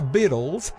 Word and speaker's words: Beatles: Y se pronuncia Beatles porque son de Inglaterra.